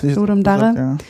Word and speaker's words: Ludum Dare: Ich gehöre zu den Verfechtern von, das kommt aus dem Lateinischen, ja, Ludum Dare.